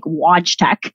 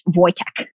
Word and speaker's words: Wojtek: Wojtek is how you pronounce it because it's Polish, right? Okay. So like I said, it looks like it would be like Wojtek, but it's apparently pronounced Wojtek.